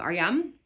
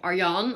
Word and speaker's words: Adriaan: Of Adriaan, maar uitgesproken Arjaan. En, of Arjan, als je het op zijn vakkees zegt.